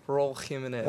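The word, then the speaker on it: Raúl Jiménez: Raul Jimenez.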